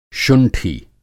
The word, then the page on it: शुंठी: Hindi